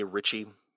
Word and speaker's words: Ritchie: Ritchie. And my last name is spelled R-I-T-C-H-I-E.